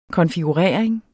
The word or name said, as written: konfigurering